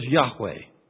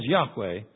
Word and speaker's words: Yahweh: But the name of the God of the Israelites, the personal name of the true God, was Yahweh. That's probably the way it was originally pronounced.